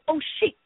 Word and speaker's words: osheq: So for that person, praise God, the word oppression, Hebrew word, it's a Hebrew word, mean obscure, O-S-H-E-Q, pronounced oshik, meaning oppression or extortion.